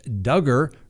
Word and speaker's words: Duggar: Misinformation going around regarding the Josh Duggar, rhymes with hugger, case.